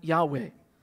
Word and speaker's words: Yahweh: But when it's capitalized, it's actually referring to God's special name, his covenant name, often pronounced Yahweh.